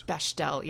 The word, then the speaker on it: Bechdel: It's Bechdel, yeah. – Bechdel? – It's actually, I think I put an L in there and it's no L. It's just Bechdel. – Bechdel.